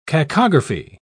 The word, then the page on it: cacography: British English